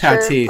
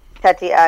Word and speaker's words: Tatis: so they have how do you pronounce it? Tatis? I'm not Tatis. – Sure. I'm from San Diego. Tatis.